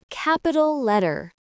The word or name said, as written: capital letter